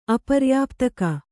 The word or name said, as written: ಅಪರ್ಯಾಪ್ತಕ